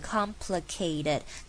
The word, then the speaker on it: complicated: OK， complicated 这个字是非常常见到的字， complicated，记住它的声调是的的的的，一阶阶往下的 complicated。然后有一个小技巧可以告诉你们， ked 这个地方 ted 我们会把它念成像是 ded，就是 ked ked。那请不要傻傻的直接念 kated，这样不好听也太刻意了，我们最自然的方法就是把它念成像 d 的声音，就变成 complicated complicated，就会很好听。